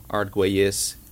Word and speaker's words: Arguelles: My name's Uriel Arguelles.